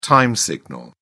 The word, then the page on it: time signal: British English